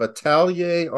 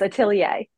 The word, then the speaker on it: atelier: We should say that is the director of the School of Atelier Arts. Did I say that right? – You're pretty close. Atelier.